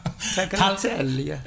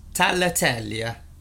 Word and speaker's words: tagliatelle: No, don't say the G. That's the point. No G's. – Tagliatelle.